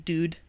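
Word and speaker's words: doodh: Okay, it is pronounced dude. It's D-O-O-D-H. I just want to make sure before dude. I just said dude. It is dude with a slightly longer O than like dude, you know?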